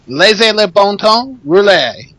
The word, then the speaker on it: Laissez le bon temps rouler: Laissez le bon temps rouler.